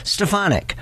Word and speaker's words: Stefanik: And that is, by the way, how she pronounces her name. I've heard her say her own name. That's how she says it. Stefanik. And you can write it out. It's S-T-E-F and then A-H capital, because that's where the emphasis is.